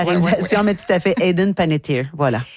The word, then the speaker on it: Hayden Panettiere: Mais malheureusement, on souligne son décès au très jeune âge de 36 ans du côté de Hayden Panettiere. – Hayden Panettiere. – Ah, c'est ça. J'ai fait une inversion de syllabe. Panettiere.